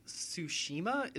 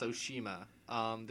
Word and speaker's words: Tsushima: It's Ghost of Tsushima is, I believe, the way the title Tsushima. – Is. Tsushima.